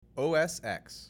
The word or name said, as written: OS X